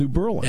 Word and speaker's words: New Berlin: It's like, don't say Berlin. – Right. New Berlin. – It's like the phone lines will light up from people from Union County. – Right. But it's pronounced New Berlin.